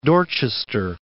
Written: Dorchester

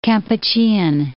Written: Kampuchean

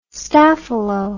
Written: staphylo

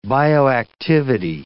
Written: bioactivity

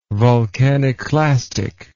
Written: volcaniclastic

